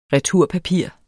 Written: returpapir

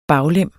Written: baglem

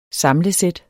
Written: SAMLET

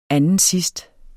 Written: andensidst

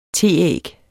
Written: teæg

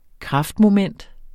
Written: kraftmoment

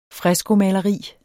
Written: freskomaleri

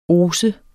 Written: oser

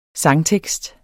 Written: sangtekst

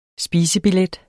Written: spisebillet

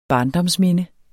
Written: barndomsminde

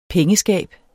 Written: pengeskab

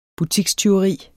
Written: butikstyveri

